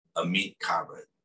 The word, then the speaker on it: Ameet Khabra: I am honored and excited to have with me today the one and only Ameet Khabra.